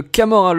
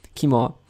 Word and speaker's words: Kimura: Il évite Strowman à un moment et tente le Camorra Lock. Lesnar est accroché au corps. À chaque fois, je sais pas. – Kimura.